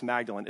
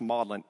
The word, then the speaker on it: Magdalen: By the way, a quick side note, it looks like it should be pronounced Magdalen. It's actually pronounced Magdalen because the British are weird that way.